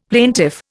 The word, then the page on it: plaintiff: Indian